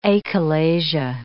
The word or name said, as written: achalasia